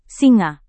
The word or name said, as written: singa